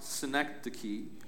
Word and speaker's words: synecdoche: This word is pronounced synecdoche.